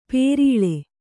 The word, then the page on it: ಪೇರೀಳೆ: Kannada